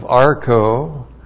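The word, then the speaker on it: archō: A-R-C-H long O. Archo.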